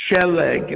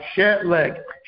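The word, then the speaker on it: Sheleg (שלג): Sheleg. It's a double segulate noun. Sheleg. Shin lamad gimel. Sheleg.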